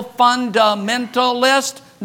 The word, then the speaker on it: fundamentalist: They used to call people that believe the Bible fundamentalist. Notice the first syllable, fun. Not sad dementalist. Not depressed dementalist.